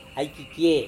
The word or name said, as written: aikikiei